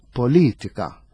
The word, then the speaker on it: politika: Allura qiegħda t-tielet sillaba mill-aħħar politika, politika. Dak huwa aċċent aħna ngħidu l-użdruċċjolu għax meħud mit-Taljan.